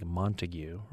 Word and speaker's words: Montague: Althea says, I've always wondered about a county spelled M-O-N-T-A-G-U-E, which I'm assuming we would, if you looked at it and didn't know, you might think Montague, right? But it's pronounced Montague, as Althea points out.